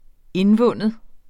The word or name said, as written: indvinde